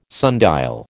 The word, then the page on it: sundial: American English